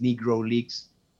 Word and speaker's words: Negro Leagues: Entonces, también existió el símbolo de las Negro Leagues o las ligas negro, pero se pronuncia en inglés Negro Leagues.